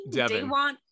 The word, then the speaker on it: Devon: Is it Devin? – Devon.